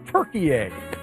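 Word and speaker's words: Türkiye: The United States plays in a group including Paraguay, Australia, who they play on Friday, and then Turkey A. – Is Turkey A basically Turkey and we just changed the pronunciation? – Correct, yes.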